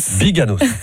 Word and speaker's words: Biganos: Biganos, voilà.